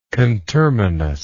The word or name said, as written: conterminous